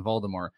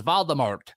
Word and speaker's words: Voldemort: Luke P, my guy, says, oh, by the way, the pronunciation in the movies, J.K. Rowling intended it to be silent for Voldemort.